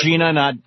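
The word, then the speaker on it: Jena: Back it's pronounced Gina, not Jenna. It's pronounced Gina? – Yeah, Gina. Yeah.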